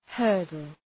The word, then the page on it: hurdle: ‘hɜ:rdəl